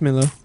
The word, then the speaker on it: Millar: Millar, is it Millar? – It's Millar.